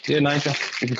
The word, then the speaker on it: Niger: It hasn't got two Gs, it's got one in there, so you pronounce it as it is, Niger.